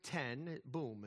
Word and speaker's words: Ten Boom: Corrie Ten Boom. It's just like it's spelled. T-E-N-B-O-O-M. Ten Boom.